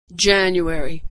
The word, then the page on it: January: dzh